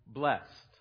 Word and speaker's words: blessed: The word I knew was blessed. There was an emphasis on the ED at the end. And as a curious and inquisitive little boy, I would wonder, why is it that this word gets treated differently, as if it adds an extra syllable?